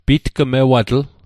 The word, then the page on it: pitgmewatl: Mi'gmaq